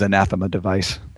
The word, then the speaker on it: Anathema Device: Well, the way Martin Jarvis says it is Anathema device.